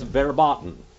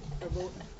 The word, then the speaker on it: verboten: That was verboten. I didn't say that right, did I? – Verboten. – Verboten. – That's right.